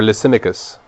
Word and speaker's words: Lysimachus: And those four empires were ruled by Cassander, Lysimachus, or Lysimachus, that's how you pronounce it. Cassander, Lysimachus, Seleucus, and Ptolemy. That's spelled P-T-O-L-M-Y.